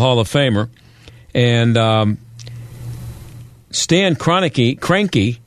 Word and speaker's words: Kroenke: The guy, the owner's name, I believe is pronounced Kroenke, Stan Kroenke, Kroenke.